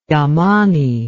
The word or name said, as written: Yamani